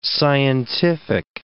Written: scientific

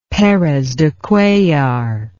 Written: Perez de Cuellar

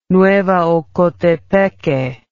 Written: Nueva Ocotepeque

